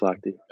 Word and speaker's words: Lochte: of Michael Phelps and Ryan, always butcher his last name. Is it Lochte? – Yeah, it's Lochte.